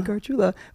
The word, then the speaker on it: Gargiula: And then, yeah, my social media is just my name, Anthony Gargiula, which Spencer does not know how to pronounce.